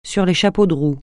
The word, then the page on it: sur les chapeaux de roue: French